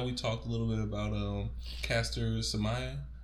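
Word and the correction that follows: Semenya: Mm, I'm sorry. Seminai, sorry. Right? Seminai. I'm mm-hmm. sorry for butchering her name.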